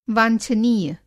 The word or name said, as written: वांछनीय